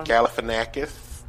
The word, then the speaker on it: Galifianakis: Zach Galifianakis.